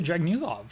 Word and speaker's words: Dragunov: We've got Walter and Ilya Dragunov. Is that how you say it? – Yes. – Okay. – He's Dragunov.